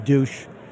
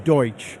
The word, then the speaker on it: Deutsch: I know some of you are going to call in and correct me and say that's pronounced douche. It's not. That may be what he is, but it is pronounced Deutsch.